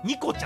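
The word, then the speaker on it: 虹心: あ、そういえば何でしたっけ、今日ちょっと衝撃的なお名前を見たんですけど、虹の心とか言って、これそう、お子様の名前なんですけど、そう、虹の心と書いて、ニコちゃんらしいです。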